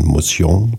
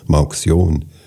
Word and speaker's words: Mauxion: Die Firma nannte sich französisch ausgesprochen Mauxion. Die Saalfelder haben immer gesagt Mauxion.